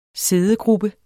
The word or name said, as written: siddegruppe